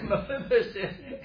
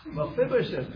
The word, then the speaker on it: Mephibosheth: Mephibosheth. – You just earned 50 cents. Mephibosheth, very good.